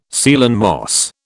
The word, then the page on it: Ceylon moss: British English